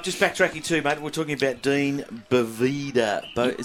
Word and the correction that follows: Dion Boavida: Is that how we say his name? – Dion Boavida. – Dion Boavida. I've got that completely wrong.